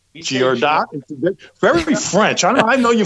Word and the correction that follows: Girardot: But you know, if you, okay, so it's a French last name, the T is silent, like Merlot or Brigitte Bardot. – Girardot. – There you go. – Yeah, guys. – Girardot.